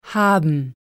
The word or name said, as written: haben